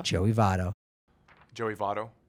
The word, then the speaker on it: Joey Votto: Joey Votto.